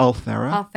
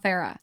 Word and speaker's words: Ulthera: Ulthera. – Ulthera.